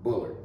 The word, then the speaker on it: Bullard: B-U-L-L-A-R-D, Bullard, right? Am I saying that right? – Bullard. – Yeah. – All right. – You know, like Chris Bullard.